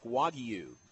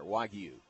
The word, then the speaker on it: Wagyu: And he actually said it's more like Wagyu. Put the Y in there, Wagyu. Wagyu. – Now, Coleman's getting really exotic. – Wagyu. – He said nothing about Wagyu.